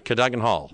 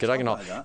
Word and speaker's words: Cadogan Hall: Is that how you say it? – Yeah. – I've heard different things, but Cadogan Hall. – Yeah, that works. – Okay, that works. – Cadogan Hall.